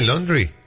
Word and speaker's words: laundry: L-A-U-N-D-R-Y. – Laundry. – Exactamente. La laundry. Todo diptongo. Que en inglés es AU, se pronuncia como O. Es como la marca del auto Audi.